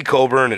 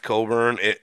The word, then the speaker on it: Cockburn: Kofi Coburn is such a beast, though, and I don't know how his name is pronounced Coburn when it's spelled Cockburn, but, you know, it's not my name.